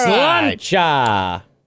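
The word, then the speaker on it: Sláinte: Did I say it right? Slancha? – Slancha! – Right.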